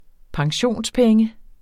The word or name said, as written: pensionspenge